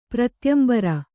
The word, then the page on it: ಪ್ರತ್ಯಂಬರ: Kannada